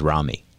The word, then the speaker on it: Ramy: It's called Rami, R-A-M-Y, but I think the correct pronunciation is Rami.